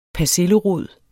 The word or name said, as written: persillerod